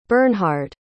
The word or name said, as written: bernhard